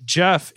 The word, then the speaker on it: Gef: According to the Irvings, a creature named Jeff introduced. Now, by the way, this is spelled G-E-F in case you want to Google this.